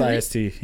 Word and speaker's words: IST: It's just IST, yeah. IST Entertainment, I'm pretty sure.